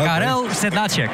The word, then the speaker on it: Karel Sedláček: Daar ging de klemtoon zo goed. Want... – Nee, dat is Karel. – Oh, Karel. – Okay. Karel Sedláček.